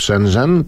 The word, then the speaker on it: Shenzhen: Pierwotnie tak, w Shenzhen, teoretycznie się tak wymawia.